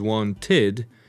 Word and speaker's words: wanted: Now, want in the past is wanted. Here you pronounce id, wanted.